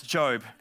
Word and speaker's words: Job: It's spelt Job, but it's Job.